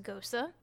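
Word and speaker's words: gose: So this one is a pineapple, kiwi, passion fruit, and guava gosa, which I think I called it a gauche before, but I think it's pronounced gosa.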